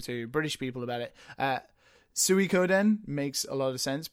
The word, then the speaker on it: Suikoden: It's Suikoden 2. How do you pronounce it? – Suikoden 2, yeah.